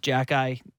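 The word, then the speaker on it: Xhekaj: Yeah, the last name is spelled like X-H-E-J-A. I don't have it in front of me. But yes, it's very complicated looking, but pronounced Jack Eye.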